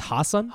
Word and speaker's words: Hasan: So they asked Hisson if am I saying his name right? – Hisson? I think it's Hasan.